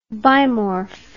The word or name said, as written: bimorph